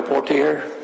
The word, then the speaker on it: Portier: Okay, I'm Joe Portier. – And can you spell that last name? – P-O-R-T-I-E-R.